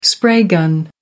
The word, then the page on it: spray gun: American English